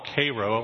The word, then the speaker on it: Cairo: However, everyone in the small town that my grandparents lived in, it's actually, they call it Cairo, although it's spelled like Cairo, they believed that this courthouse that was just one town over was the one on the back of the nickel.